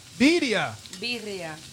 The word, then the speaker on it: birria: How do you pronounce it? How do you Birria. – Say it? – Birria. – Birria. – Yes.